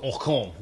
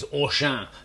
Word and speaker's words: Auchan: There's a lot to discuss, but I probably should start off by saying I don't think it's called Ocon. The French read it. it my, my, French pronunciation was awful. I think it's Auchan. There you go. That's much better, isn't it?